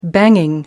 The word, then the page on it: banging: American English